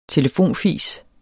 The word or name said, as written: telefonfis